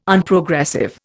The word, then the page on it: Unprogressive: Indian